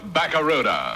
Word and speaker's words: Barracuda: And right now I'm giving the best deal ever on that new Plymouth Barracuda. – Hey, man, the name of the Plymouth Fastback is the Barracuda.